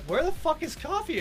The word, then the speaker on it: Ko-fi: It's actually pronounced coffee.